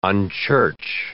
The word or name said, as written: unchurch